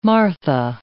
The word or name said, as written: Martha